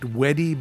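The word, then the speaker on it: WEDI: And that's why I think it's so very important for all of you to know about Weedy, which, by the way, should be pronounced Weddy, but we'll get into that later. Weedy is the Westminster Economic Development Initiative, which I've already told you about.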